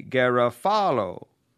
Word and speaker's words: Garofalo: So is she your third cousin, and why does she pronounce it so un-Italian? It should be Garofalo.